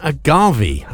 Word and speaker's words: agave: Now, Kew Gardens is home to a special plant, a death bloom agave, I've discovered that's how to pronounce it, planted around 20 years ago.